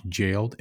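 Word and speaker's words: Gaoled: Like when you would see Gowler, right? Uh-huh. That's Jailer. That's like the old English spelling of jailer. – Sure. That makes sense. – I think it's actually pronounced jailed, and there's a picture of a jail on there's like medieval style artwork on there too.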